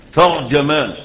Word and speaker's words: ترجمان: الأشهر الغالب فيها الفتح، ترجمان، وهي اللغة أو الصورة التي ورد بها الحديث عند البخاري وعند غيره، والصورة الغالبة على ألسنتنا ان احنا بنقول ايه؟